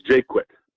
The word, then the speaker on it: Jaquette: Am I pronouncing that right, or do we say it like the saxophone player? – Jake quit.